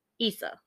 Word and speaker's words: Issa: But um, in there, in 1995, she meets the handsome ER doctor Yazid Issa. I keep wanting to say Essa because the way it's spelled, but it's E S S A. It's Issa, and he is um American-born, first-generation Palestinian in the United States.